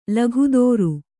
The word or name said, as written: ಲೋಕೋತ್ತರ